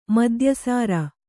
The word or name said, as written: ಮದ್ಯಸಾರ